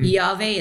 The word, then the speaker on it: EAVE: Yave is spelled E-A-V-E. Took us a long time to get that around our tongues around that word.